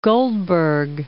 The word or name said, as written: Goldberg